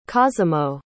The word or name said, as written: cosimo